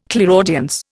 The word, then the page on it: clairaudience: Indian